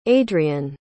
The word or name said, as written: aidrian